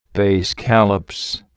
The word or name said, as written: bay scallops